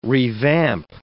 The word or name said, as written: revamp